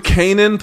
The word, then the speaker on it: Canaan: New Canaan Police, am I pronouncing that word right? With the two A's like that? – Yeah, yeah.